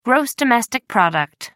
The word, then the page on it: gross domestic product: British English